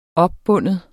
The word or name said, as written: opbinde